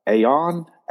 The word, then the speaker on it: aion: It actually is the word, and I think it's pronounced aion, A-I-O-N.